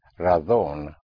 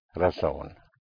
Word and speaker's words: razón: The word for reason, spelled R-A-Z or Z-O-N, in Castilian Spanish will be pronounced razón, otherwise razón. So it's a very, very slight pronunciation difference.